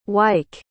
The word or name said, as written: wyke